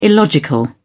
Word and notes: illogical: British English

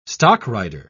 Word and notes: stockrider: British English

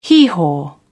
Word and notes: hee-haw: British English

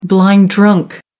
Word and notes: blind drunk: American English

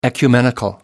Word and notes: ecumenical: American English

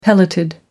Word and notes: pelleted: American English

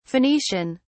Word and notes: phoenician: British English

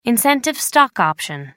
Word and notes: incentive stock option: American English

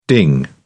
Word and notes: ding: British English

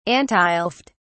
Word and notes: antileft: British English

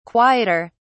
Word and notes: quieter: British English

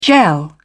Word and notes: gel: British English

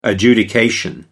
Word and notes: adjudication: American English

